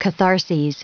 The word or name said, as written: catharses